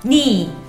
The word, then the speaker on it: 니: Sí, en esta simplificación hay un problema, ya que se escribe como ne. Pero como recordemos, en Corea existen dos tipos de e. Y como queda y se pronuncia igual al otro ne, que significa mi, por eso se pronuncia ni.